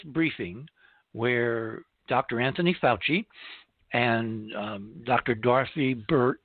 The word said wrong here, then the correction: Birx: Birx, I'm going to pronounce that correctly, with an X, are leaving the stage.